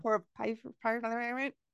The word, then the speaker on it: porphyria: porphyria.